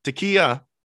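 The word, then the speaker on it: Takeo: This sort of sounds like the word you shout for a long shofar blast during the high holy days of Judaism. Takiyo.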